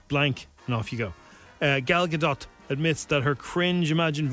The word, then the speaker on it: Gadot: Yeah, we thought it was Gal Gadot for ages. – Everyone called her Gal Gadot. It's Gal Gadot. – It's more, you know, Gal Gadot.